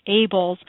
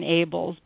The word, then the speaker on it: Abels: Well, thank you for having me, and it's actually, I will tell you, it's Abels, and I take no responsibility for how it's pronounced since it is, after all, my husband's name, and it really looks like it should be anything other than Abels, but...